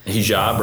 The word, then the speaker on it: Hijab: Hijab, right? – I think Hijab.